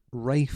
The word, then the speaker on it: Ralph: I was going to talk about names very quickly just because you've got Rafe Fiennes, a man who is so posh he doesn't know how to say Ralph.